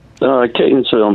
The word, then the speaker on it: Catonsville: Catonsville, Maryland.